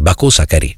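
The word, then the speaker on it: Bakari Sako: Cioè, qui abbiamo questo Bako Sakari, perché io ieri chiamavo Bako Sakari, ma in realtà c'è l'accento sull'ultimo, quindi Bako Sakari, ok?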